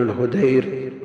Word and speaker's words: الهدير: يقول حدثني عن مالك عن محمد بن المنكدر يعني ابن عبد الله ابن الهدير ضبطوه بالتصغير التيمي